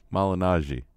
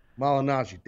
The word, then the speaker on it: Malignaggi: If I would have shot an angle with Paulie, you know, I mean, Paulie uh, uh, um, Malieri, Malieri. How do you say? I always freak up his name. Malignaggi. Malignaggi.